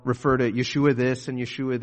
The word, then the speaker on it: Yeshua: Now, in Hebrew, there is no letter that makes the J sound. And so if you were to say it straight from Hebrew, we wouldn't pronounce it Joshua. We would say Yeshua.